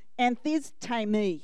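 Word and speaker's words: anthistēmi: All right, you ready for the Oka pronunciation of this awesome Greek word? Anthisti. I got it wrong. Try again. I even practiced too. Anthistami.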